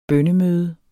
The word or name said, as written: bønnemøde